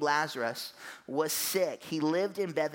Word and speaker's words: Lazarus: I have this blank, but I do remember my sister bagging on me to no end because I could not pronounce Lazarus.